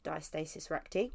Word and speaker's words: diastasis recti: Obviously, we've already spoken about, let me say it again, diastasis recti.